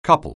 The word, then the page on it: couple: anglais